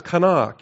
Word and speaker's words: qanah: But the verb is kana, Q-A-N-A-H is how you spell it when you transliterate it into the English.